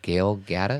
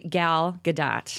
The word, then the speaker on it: Gal Gadot: Gal Gadot? – It's actually Gal Gadot.